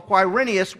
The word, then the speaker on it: Quirinius: So Caesar Augustus called for a census while Quirinius was high priest.